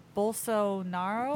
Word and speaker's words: Bolsonaro: After tweeting a pornographic video to denounce one of his country's biggest festivals, Brazilian President Jair Bolsonaro. How do you say his name? Bolsonaro.